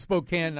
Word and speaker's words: Spokane: Yes, and it's Spokane, not Spokane.